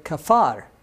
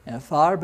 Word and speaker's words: Kfar Nahum: Kfar, because it didn't have that dot in the middle, so it's not a puh like Capernaum. It's Kfar, Nahum. Okay? So that's how you say it Bivrit, or in Hebrew. You could say Capernaum if you want.